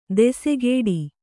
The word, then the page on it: ದೆಸೆಗೇಡಿ: desegēḍi